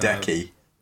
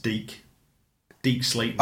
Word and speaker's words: Deke Slayton: Although I think it's pronounced Deke Slayton. – Oh, sorry, Deke.